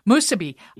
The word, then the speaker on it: musubi: And then when I started talking to you last year and I heard about it, am I saying it right, Musubi? – Musubi.